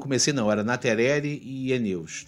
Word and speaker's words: aeneus: Geralmente, coridora albina é da espécie Coridoras aeneus, Eneus que fala. Eu falei, eu falei Aeneus, mas é Eneus que pronuncia.